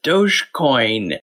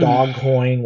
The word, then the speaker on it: Dogecoin: Okay, first of all, you pronounced it right. We get questions about how that's said. And I've heard dog coin, which is not correct.